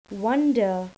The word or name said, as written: wonder